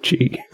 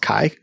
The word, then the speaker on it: Chi: 95% sure it's pronounced Kai. And I know this because the Kai Rho was the symbol that, you know...